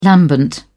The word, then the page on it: lambent: British English